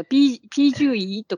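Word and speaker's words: 啤酒鱼: えー、ビール、ビール魚って書くんですよ。ピージュイとか言って読むんですけど、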